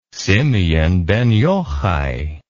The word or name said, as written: simeon ben yohai